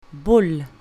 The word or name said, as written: bull